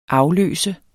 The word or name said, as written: afløser